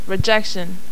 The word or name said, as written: rejection